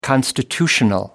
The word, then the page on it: constitutional: American English